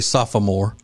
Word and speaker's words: sophomore: He will return a sophomore. Actually, sophomore. – Sophomore. – Yeah, there's an extra syllable in there. Sophomore, which a lot of people don't know.